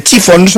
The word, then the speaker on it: Chiffons: Años antes había triunfado otra creación, He's So Fine, compuesta por Ronald Mack e interpretada por The Chiffons, no sé si lo he dicho bien. – Lo has dicho estupendamente.